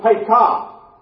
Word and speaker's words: Pekah: Along with Pekah. Accents on the last part of the word there.